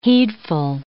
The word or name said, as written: heedful